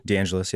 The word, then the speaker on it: DeAngelis: DeAngelis, yeah. – DeAngelis, okay. – Like Los Angeles.